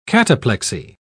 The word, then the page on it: cataplexy: British English